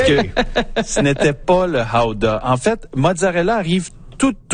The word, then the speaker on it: gouda: Ou 3, le gouda, qu'il faut prononcer d'ailleurs howda. – Ooh, howda. – Howda? – Oui. En irlandais. C'est howda. Oui.